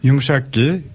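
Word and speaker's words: yumuşak ge: Yumushak Ge, das spricht man fast kaum aus, also R ist es im Türkischen.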